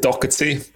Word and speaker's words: Doherty: Yeah, my name's really weird. It's pronounce it Doherty, but I just Doherty, say Doherty okay. To people, just easier. It's an Irish name, but yeah. Either way is cool with me.